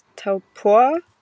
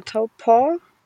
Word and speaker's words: Taupō: Ähm, äh, ich habe äh, hab gesehen, Taupo schreibt man mit einem äh, Strich über dem O und der Strich über dem Buchstaben ist immer die Darstellung dafür, dass das ein äh, langer Vokal ist.